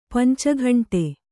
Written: ಪಂಚಘಂಟೆ

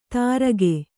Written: ತರ್ಕೆ

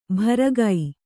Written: ಭರಗೈ

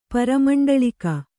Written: ಪರಮಂಡಳಿಕ

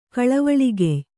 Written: ಕಳವಳಿಗೆಯ್

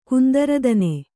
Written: ಕುಂದರದನೆ

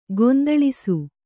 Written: ಗೊಂದಲಿಸು

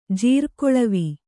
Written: ಜೀರ್ಗೊಳವಿ

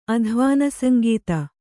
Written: ಅಧ್ವಾನಸಂಗೀತ

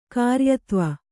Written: ಕಾರ್ಯತ್ವ

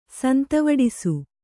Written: ಸಂತವಡಿಸು

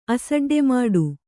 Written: ಅಸಡ್ಡೆಮಾಡು

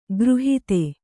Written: ಖುರಾಹತಿ

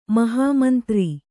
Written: ಮಹಾಮಂತ್ರಿ

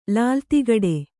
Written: ಲಾಲ್‍ತಿಗಡೆ

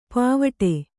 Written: ಪೋವಿಡು

